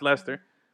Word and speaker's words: Leicester: Which is spelled Leicester, Leicester. I can't even pronounce it, but it's pronounced Leicester.